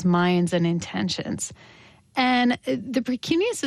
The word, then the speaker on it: precuneus: Tell me if I'm pronouncing this correctly. The Precuneus? – Yes, the Precuneus. Very nice.